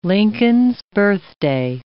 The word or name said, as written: Lincoln's Birthday